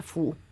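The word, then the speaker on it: Fu (福): y después, una vez que le hacen el carácter, favorito de los chinos para hacer esto, que se pronuncia Fu y que significa buena suerte, ella y su marido lo muestran boca abajo.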